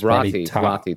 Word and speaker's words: Rottytops: R-O-T-T-Y? – Well, I Googled something not right. – Okay, it is R-O-T-T-Y-T-O-P-S. – Oh, Roddy Top. – Like rotten.